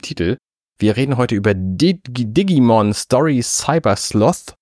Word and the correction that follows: Sleuth: Ich glaube, es heißt Sleuth. Es wird Sleuth ausgesprochen.